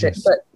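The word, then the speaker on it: Regius: How do you pronounce it? – Codex Regis, I pronounce Regis. Other people do it differently.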